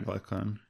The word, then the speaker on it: Glaucon: They appear to take a break with discussing this whole like analogy, and he's talking to this how do you pronounce it? Glaucon? – Yeah, Glaucon.